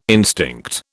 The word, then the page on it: instinct: British English